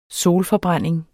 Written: solforbrænding

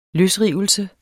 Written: løsrivelse

